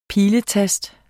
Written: PILETAST

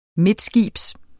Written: midtskibs

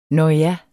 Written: nåja

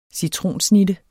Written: citronsnitte

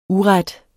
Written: uret

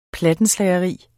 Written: PLATTENSLAGERI

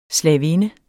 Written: slavinde

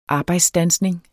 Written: arbejdsstandsning

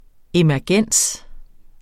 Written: emergens

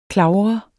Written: klavre

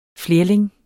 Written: flerling

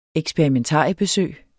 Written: eksperimentariebesøg

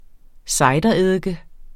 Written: cidereddike

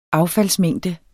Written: affaldsmængde

